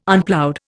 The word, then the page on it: Unploughed: Indian